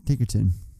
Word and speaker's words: Ticotin: Is uh, Paul Newman's partner, and then um, Rachel Ticotin, I, I, think is how you say her name from um, Total Recall. – Yeah, Ticotin.